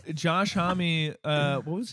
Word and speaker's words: Homme: Them Crooked Vultures is a super group made up of Dave Grohl, John Paul Jones, and Josh Holm. – Right, John Paul Jones. – Josh Homme. – Is that how you say it? – I think it's Homme, yeah.